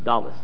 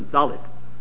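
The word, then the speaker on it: Dalet (דלת): Incidentally, you notice that the real word for Dalet is not Dalet, but Dalas. – Dalas. – No, Mela. If you pronounce it according to the Israeli pronunciation, so it comes out as Dalet.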